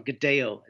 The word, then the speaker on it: Godello: The grape there is Godello, or Godello is how they would pronounce it, G-O-D-E-L-L-O.